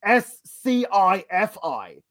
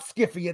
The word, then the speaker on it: Syfy: It used to be pronounced S-C-I-F-I. But people would say that looks like Skiffy. And it's not Skiffy. It's Sci-Fi, but it's spelled correctly. Now, they changed it to the S-Y-F-Y.